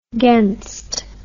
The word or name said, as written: gainst